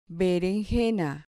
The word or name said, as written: berenjena